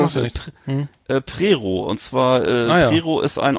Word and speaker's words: Prerow: Und da kann man da gibt es einen Campingplatz, der heißt das der Regenbogen Regenbogencamping Prerow, geschrieben für Westdeutsche Prerow mit OW am Ende. Wird aber O ausgesprochen, so wie die ganzen Orte.